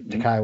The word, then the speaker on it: Takei: Speaking of George Takei, that one in California, that's where George Takei was as well. – It's Takei. – Takei, sorry.